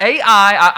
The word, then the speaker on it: Ai: And I always struggle, like, how do I pronounce that word? Is it A? Is it, Ai? Right, but Ai, I even pulled out my little Bible app and turned on people who are professionals who are reading the Bible. It's pronounced Ai is how everybody's pronounced it, all right?